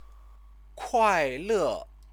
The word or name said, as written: kuaile